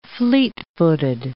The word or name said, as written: fleet-footed